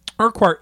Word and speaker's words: Urquhart: Urquhart, and am I saying that right? – Yes.